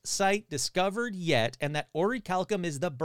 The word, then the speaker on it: orichalcum: And you're going to say, that's not how it's pronounced. Bullshit. I looked it up like 50 times. It's orichalcum.